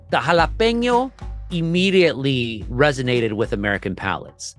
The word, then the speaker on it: jalapeño: Try jalapeño. The J is pronounced like a ja. Then you have the little tilde, of course, on top, the little squiggly thing in the N, which turns into an ñe.